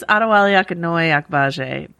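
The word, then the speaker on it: Adewale Akinnuoye-Agbaje: I think it's Adewale Akinnoye Agbaje, based on the pronunciation guide you gave me.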